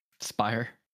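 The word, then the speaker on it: Speier: We got Brash, Sewald, got Saucedo, Spear. How do you say it? Sperrier? Spire.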